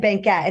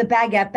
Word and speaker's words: baguette: Oh, um, you know, I think I've got everything I've coveted, but it was mostly the Fendi stuff, the bank, the baguette bags. Did I just say that right? – Baguette yeah, baguette, baguette, like the baguette bread. Bag. – I almost said banquette, and I'm like, no, that's not right.